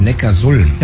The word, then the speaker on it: Neckarsulm: Nee, ich glaube, das heißt Neckarsulm. – Ja, und warum? – Ja, weil es in der Datenbank steht. – Nee. Die heißt deshalb Neckarsulm, weil Ulm liegt an der Donau.